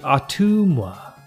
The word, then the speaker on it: Ottumwa: Nope. It's actually Ottumwa.